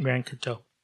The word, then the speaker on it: Grand Coteau: Grand I feel like you're not saying it right if it is a word. – Grand Coteau.